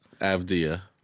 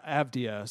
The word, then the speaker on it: Avdija: I don't know how to pronounce his last name, but Avdija. – Yeah, Avdija.